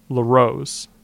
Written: LaRose

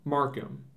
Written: Markham

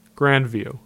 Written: Grandview

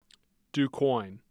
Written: Du Quoin

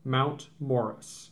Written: Mount Morris

